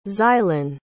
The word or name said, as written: xylon